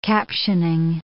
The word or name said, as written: captioning